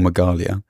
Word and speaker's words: Meghalaya: The state of Meghalaya in India, or Meghalaya, it may be pronounced, in northeast India.